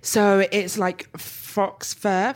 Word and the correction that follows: faux: Fox? I always can never pronounce that shit. – Faux fur. – Faux fur.